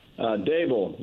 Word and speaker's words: Daboll: What is the right pronunciation? – Daboll, like a table.